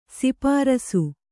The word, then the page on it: ಸ್ಪರ್ಶ: sparśa; Kannada